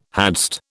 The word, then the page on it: hadst: British English